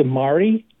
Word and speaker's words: Mari: And it's spelled M-A-R-I, not M-A-R-Y. – So Mari? – Yeah, it must be Mari.